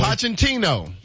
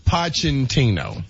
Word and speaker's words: Pochettino: Mauricio Pochettino. – Pochettino? – Pochettino.